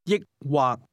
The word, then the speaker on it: 抑或: This word is pronounced with a combination of high and low level tones. Say or. – 抑或抑或 Try to ask, do you want to drink beer or wine?